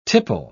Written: tipple

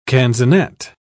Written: canzonet

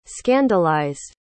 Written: scandalized

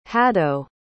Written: hadow

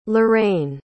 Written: lorayne

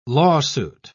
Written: lawsuit

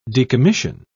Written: decommission

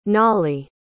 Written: nolie

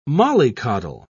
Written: mollycoddle

